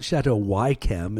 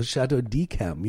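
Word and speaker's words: Château d'Yquem: It's not Chateau Wychem. It's a Chateau Dechem, you know, to use a pithy example.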